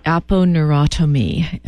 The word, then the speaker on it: aponeurotomy: Aponeurotomy. A-P-O-N-E-U. And N-E-U. – Yeah, Google will find the rest of it for you. It'll R-O-T-O-M-Y. Yeah. Neurotomy. It's spelled like it sounds.